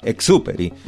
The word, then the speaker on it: Saint-Exupéry: Tiene acento en la E, por eso dije Exupéry y no Exupéry.